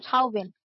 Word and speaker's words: Chauvin: Chauvin.